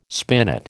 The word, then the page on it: spinet: American English